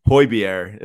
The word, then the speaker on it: Højbjerg: Dude, I wouldn't expect you to notice unless you watched the match, because I didn't know until I watched it, but it's pronounced Højbjerg. Isn't that crazy? – Even more Denmark.